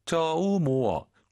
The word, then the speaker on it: 周末: 쪼모愉快. – 오유는 어예요. 쪼가 아니라 쪼, 모가 아니라 모어, 쪼모어.